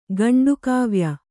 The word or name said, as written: ಖಂಡಕಾವ್ಯ